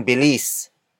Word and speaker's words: bilis: Magkasing bilis ang tigre at ang zebra.